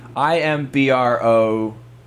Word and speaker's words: imbroglio: Okay, let me try it now. Imbroglio. – That's correct.